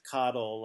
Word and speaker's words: Cottle: Because he's, you know, Caudill, of course, the character name is spelled C-O-T-T-L-E, but if you think of it as Caudill, like C-O-D-D-L-E, you get the sense that, like, it's a very ironic name.